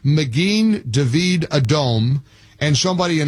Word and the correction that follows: Magen David Adom: Alpharetta, California, or Georgia said, you're not pronouncing it correctly. It's Magen David Adom. What's the proper pronunciation, Uri? – So you got it right on the second time. It's Magen David Adom.